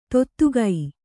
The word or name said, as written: ತೊತ್ತುಗೈ